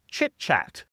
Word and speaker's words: chit-chat: And then the next phrase is chit-chat, casual, informal talk, very similar to the small talk idea. Chit-chat. And make sure that you pronounce it like a CH and not like an SH at the beginning.